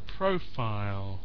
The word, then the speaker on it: profile: And profile. So it's covered. It's a stress on the first syllable.